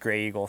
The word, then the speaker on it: Graeagle: But yeah, I guess it's pronounced Grey Eagle.